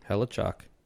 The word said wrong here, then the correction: Hellebuyck: Well, how do you pronounce that? Hellebuyck. Hellebuyck.